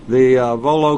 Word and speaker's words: Volo: Is Volo the right way to say it up in Illinois? – Yep, Volo Auto Museum.